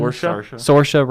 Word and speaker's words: Saoirse: How do you say her name? Cyrus Ronan? – Saoirse Ronan.